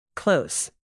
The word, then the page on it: close: shut